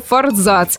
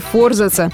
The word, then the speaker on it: форзац: Впрочем, подавляющее большинство авторитетных словарей настаивает на исключительном праве форзаца и утверждают, что ставить ударение на «а» крайне неправильно. И хоть некоторые словари допускают оба варианта, лучше все-таки придерживаться литературной нормы. А она такова. Форзац, ну и, в общем-то, форзац.